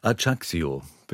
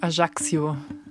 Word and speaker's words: Ajaccio: Jetzt in die Berge von, nun kommt es, ein schwieriges, aber genauso schönes Wort auch, Ajaccio. Einigermaßen richtig ausgesprochen? – Ja, war richtig. Ajaccio, sagen Sie. Ich habe es auch beim ersten Mal komplett falsch ausgesprochen.